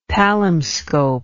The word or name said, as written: palimscope